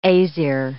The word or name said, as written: Aesir